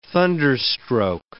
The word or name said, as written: thunderstroke